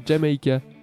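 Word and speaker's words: D'yer Mak'er: Alors prenez vos calepins, parce que je vais vous les appeler, ça s'écrit D'YER espace MAK'ER et ça se prononce Jamaica.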